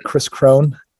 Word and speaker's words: Kris Krohn: But if you could spell it right, Chris Krohn, K-R-I-S-K-R-O-H-N, just Google it.